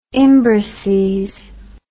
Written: imbrices

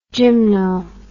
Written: gymno

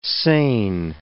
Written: sain

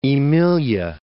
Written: Aemilia

